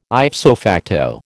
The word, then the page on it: ipso facto: American English